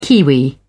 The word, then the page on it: kiwi: British English